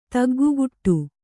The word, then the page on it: ತಗ್ಗುಗುಟ್ಟು: Kannada